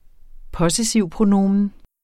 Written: possessivpronomen

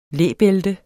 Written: læbælte

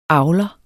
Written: Avlere

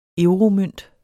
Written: euromønt

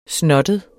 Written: snottet

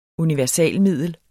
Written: universalmiddel

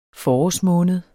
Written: forårsmåned

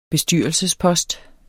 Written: bestyrelsespost

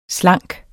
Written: slanke

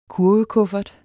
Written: kurvekuffert

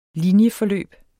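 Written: linjeforløb

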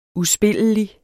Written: uspillelig

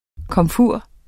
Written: komfur

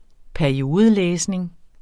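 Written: PERIODELÆSNING

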